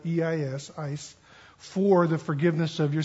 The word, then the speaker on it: eis: it's a little conjunction spelled E-I-S in the Koine Greek. It's pronounced ice, spelled E-I-S.